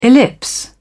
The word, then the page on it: ellipse: British English